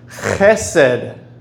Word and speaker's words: chesed: It's pronounced chesed. Everybody try to say it once. Chesed. Yeah. All right. Now, if you want to cover the back of your neck, and I'll stand a little bit further back, we can all pronounce it together on the count of three. One, two, three. Chesed. Very good. It's that hard H, and it works really good if you're sick and you have phlegm in your throat.